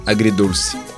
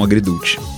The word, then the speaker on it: Agridulce: Que é La Sinfonia Agridulce. Agora falei certo, e não Agridulce. Agridulce.